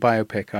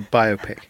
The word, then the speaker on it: biopic: I think you're talking about a biographical picture, which is a biopic. Biopic sounds like it's dealing with biopia, which is the narrowing of truth and meaning in order to win awards.